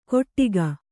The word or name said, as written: ಕೊಟ್ಟಿಗ